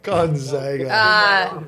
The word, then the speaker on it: Gonzaga: How do we say it? – What time is that? – Gonzaga.